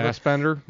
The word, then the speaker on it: Fassbender: The only other thing I got going was I watched The Killer last night on Netflix with Michael Fassbach. Did I say his name? Fassbacher, Fassbender, whatever. – Fassbender? Yeah.